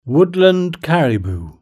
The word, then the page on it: woodland caribou: British English